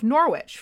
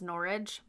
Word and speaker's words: Norwich: Alice's mother smelled something fishy, so she deposited the land deeds with the mayor of Norwich for safekeeping and freed Alice from her prison. – It's pronounced Norwich, but... – Oh, Norwich, Norwich.